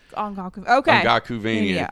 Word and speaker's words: Ongakuvania: Ongakuvania? – Yeah, like Castlevania. – Ongakuvania.